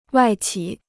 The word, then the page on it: 外企: wài qǐ